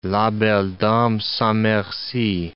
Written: la belle dame sans merci

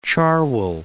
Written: Cherwell

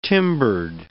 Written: timbered

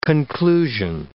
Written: conclusion

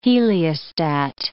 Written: heliostat

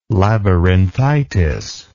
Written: labyrinthitis

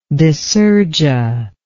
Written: dysergia

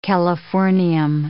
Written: californium

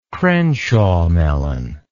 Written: crenshaw melon

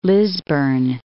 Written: Lisburn

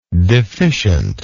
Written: deficiently

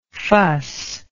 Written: fusser

